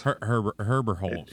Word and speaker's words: Herberholz: Herberholz.